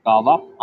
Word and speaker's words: Gò Vấp: I like one Gò Vấp. That's how you say it, I Okay. – guess. I haven't said that for so long. Yeah, Gò Vấp or District 12.